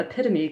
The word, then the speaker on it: epitome: When I expressed disbelief at the epitome correction, he furnished, in an instant, an unforgettable mnemonic device. It rhymes with, "You gotta be kidding me."